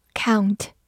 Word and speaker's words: count: Count, c o u n t, count, c 发 k，o u 字母组合发 ow，n 发鼻音嗯，t 发 t，count, count，注意不要读成 count，count，它是一个动词或者名词，表示数数或者重要。